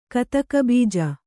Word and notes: ಕತಕಬೀಜ: Kannada

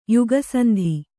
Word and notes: ಯುಗಸಂಧಿ: yuga sandhi

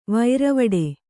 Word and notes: ವೀರವಟ್ಟ: kannada

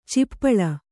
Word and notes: ಚಿಪ್ಪಳ: cippaḷa

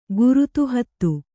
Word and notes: ಘೃತಾಹುತಿ: ghřtāhuti

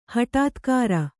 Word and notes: ಹಟಾತ್ಕಾರ: haṭātkāra

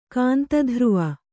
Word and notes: ಕಾಂತಧ್ರುವ: kāntadhruva

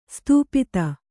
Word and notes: ಸ್ಥಪತಿ: kannada

